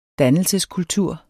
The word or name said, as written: dannelseskultur